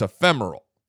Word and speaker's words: ephemeral: So it means lasting for a very short time, transition, transitory, transient, fleeting, passing, e, e- ephemeral, ephemeral. There we go an ephemeral plant. So it's ephemeral. That's how it's pronounced.